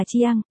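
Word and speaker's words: Chiang (姜): Chiang là Khương, nghĩa là gừng, phát âm là Chiang, đồng âm với Chiang là Giang, nghĩa là sông,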